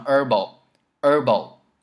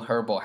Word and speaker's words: herbal: 但我们后来发现哦，原来美音的那个 Herbal 的那个 H 是不发音的，所以可以直接读成 Herbal 草本。你看它的拼写上面写的是 Herbal H-E-R-B-A-L，可以拼写出来，但是呢那个 H 可以不发音，读成 Herbal。当然英音呢你可以读 Herbal 还是没有问题的，只是这里我们注重美音，所以你可以读成 herbal，没有那个 h 的发音。